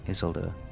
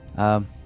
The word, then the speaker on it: Isildur: It's a Isildur spelled with an I. – Yeah, Isuldur. Isildur. Yeah, it's quite valuable.